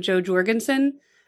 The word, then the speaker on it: Jo Jorgensen: I think you say her name, Jo Jorgensen.